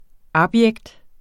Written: abjekt

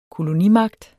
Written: kolonimagt

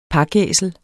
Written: pakæsel

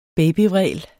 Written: babyvræl

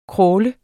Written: crawle

